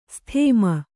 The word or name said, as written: ಸದುಮ